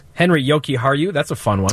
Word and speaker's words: Jokiharju: Jokiharju. – Mm-hmm. Yes. – Jokiharju.